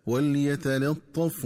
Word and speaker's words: وليتلطف: وكذلك ينبغي التنبه للنطق الصحيح للفظ وليتلطف، فيقرأ بسكون اللام وسكون الفاء هكذا وليتلطف ولا يشعرن بكم أحدا.